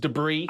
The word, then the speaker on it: debris: It's pronounced debris. I don't know why I thought that S was a Z sound, but I did.